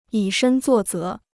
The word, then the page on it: 以身作则: yǐ shēn zuò zé